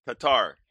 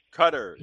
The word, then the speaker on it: Qatar: Q-A-T-A-R. – Qatar. – It's not Qatar. It's pronounced to rhyme with the dairy product, butter. – Cutter.